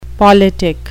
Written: politick

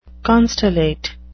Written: constellate

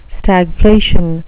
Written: stagflation

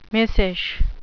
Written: missish

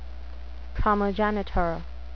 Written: primogenitor

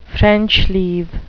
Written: french leave

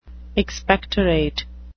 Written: expectorate

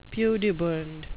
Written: pudibund

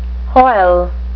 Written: hoyle

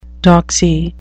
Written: doxy

